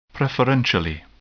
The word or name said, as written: preferentially